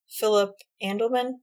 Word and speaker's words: Philip Andelman: And I think, like, with Philip Andelman, I believe how you say his name. – Yeah.